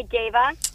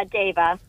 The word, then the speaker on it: Deva: Yeah, Dava. – Dava. Okay. – Can you spell it, please? – Yeah, D-E-V-A. They always call me Diva or Deva.